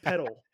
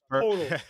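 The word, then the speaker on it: Poeltl: And then I'm going to say Jakob Pettle. It's the hardest thing to pronounce, man. – Man, I thought it was Pertle. – Jakob Pertle. I guess how you say it.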